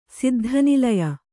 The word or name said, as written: ಸಿದ್ಧನಿಲಯ